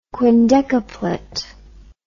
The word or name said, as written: quindecaplet